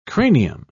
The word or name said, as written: cranium